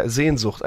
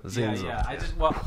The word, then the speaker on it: Sehnsucht: It's called, yeah, Sehnsucht. I should pronounce it just, or yeah. Sehnsucht. – Sehnsucht.